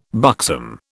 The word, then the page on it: buxom: British English